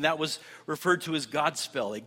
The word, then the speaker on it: Godspell: It was a word, they pronounced it Godspell, which meant a good story or a good message.